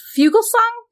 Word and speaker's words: Fugelsang: So Keith is the co-host of, I love this, the Heretic Happy Hour podcast, which has featured interviews with Bart Ehrman, John Fugelsang. Did I say that right? – Yep.